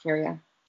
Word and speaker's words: Ciara: C-A-R-A. – I think it's It looks like Sierra. C-I-A-R-A. – Right, but it's spelled Kira. – It's pronounced Kira.